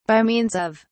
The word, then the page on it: by means of: British English